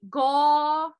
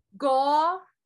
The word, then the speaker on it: Gauranga: then you say Gauranga. So it's four syllables. Gauranga.